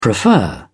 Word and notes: prefer: British English

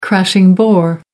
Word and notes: crashing bore: American English

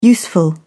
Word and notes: useful: British English

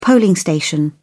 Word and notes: polling station: British English

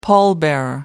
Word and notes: pallbearer: American English

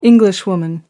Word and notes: Englishwoman: American English

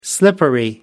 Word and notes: slippery: American English

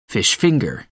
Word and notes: fish finger: American English

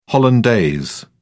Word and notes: hollandaise: British English

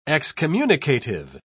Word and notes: excommunicative: British English

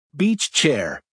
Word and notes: beach chair: American English

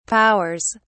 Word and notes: powers: British English

